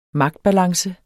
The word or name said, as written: magtbalance